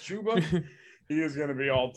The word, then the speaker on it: Chuba: 1B is Chuba, whatever. – Like a tuba. And I'm unfortunately, I think that's the correct pronunciation, but that doesn't matter.